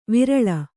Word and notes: ವಿರಲ: virala

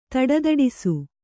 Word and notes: ಧಡಧಡಿಸು: kannada

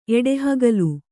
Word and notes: ಎಡಹುಗಲ್ಲು: eḍahugallu

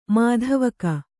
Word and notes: ಮಾಧ್ವೀಕ: mādhvīka

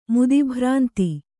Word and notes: ಮುದಿಭ್ರಾಂತಿ: mudi bhrānti

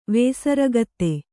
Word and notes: ವೇಸರಗತ್ತೆ: Kannada

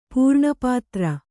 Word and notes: ಪೂರ್ಣಪಾತ್ರ: pūrṇa pātra

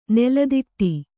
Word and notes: ನೆಲತಾಟಿ: nela tāṭi

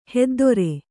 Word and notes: ಹೆದ್ದೆರೆ: Kannada

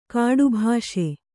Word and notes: ಕಟ್ಟುಬ್ಬಸ: kaṭṭubbasa

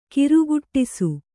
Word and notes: ಗಾರುಗೆಡಿಸು: gārugeḍisu

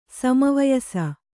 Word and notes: ಸಮವಯಸ: kannada

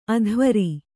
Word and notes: ಅಧ್ವರಿ: adhvari

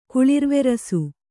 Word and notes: ಕುಳಿರ್ವೆರಸು: Kannada

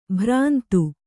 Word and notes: ಭ್ರಾಂತ: bhrānta; kannada

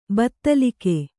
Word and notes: ಬೈತಲಿಕ್ಕು: baitalikku

